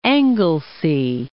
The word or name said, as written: Anglesea